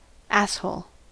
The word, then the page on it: asshole: American English